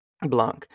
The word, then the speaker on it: Blanc: Blanc. Or J.B. Blank, I don't know how you pronounce his last name. – I think it's Blanc, yeah. – Blanc. He is the best accent voice actor in the world, in my opinion.